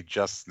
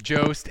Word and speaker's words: Jost: I really want to talk about how you pronounce Amy Just's name, by the way. – Yeah, Jost, and then I correct myself and say Just.